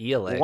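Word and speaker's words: Ialai: I don't have any hopes of even being close on this one, but Sadeas' wife, her name is Iele? Iele? – Correct. That is how they say it in the audiobook. – Are you serious? – Yeah, I'm very serious. They pronounce it Iele.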